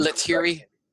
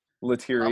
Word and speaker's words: Lettieri: Lettieri. – Lettieri.